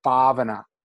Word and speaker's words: bhavana: The Pali term for meditation that we use for meditation is this word bhavana, sometimes pronounced bhavana, to cultivate.